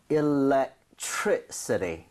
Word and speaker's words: electricity: electricity. So 이 정도의 강세를 주고 발음을 하셔야 됩니다.